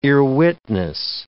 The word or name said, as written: earwitness